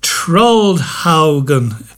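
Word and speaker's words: Troldhaugen: The wedding day at Trollhaugen. That's how you pronounce it. I seem to have been mispronouncing it all this time.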